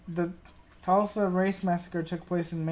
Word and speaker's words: Tulsa: And Tulsa, I don't know how to say it, Tulsa, T-U-L-S-A. Tulsa. – Tulsa? Oh, I almost said Tesla.